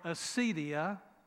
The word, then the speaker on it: Acedia: Well, Kathleen Norris, in her book entitled Acedia and Me, and that's spelled A-C-E-D-I-A,